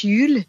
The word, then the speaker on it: Jul: « Yule », ça s'écrit « J-U-L », ça se prononce « Yule ».